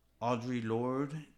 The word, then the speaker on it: Audre Lorde: And it's by Audre Lorde, if I'm saying that right.